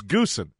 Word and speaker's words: Goosen: And I'm babbling to him like, Mr. Venturi, somebody just told me that it's Retief Goosen is pronounced Usen. He's like, the hell are you talking about? It's Goosen.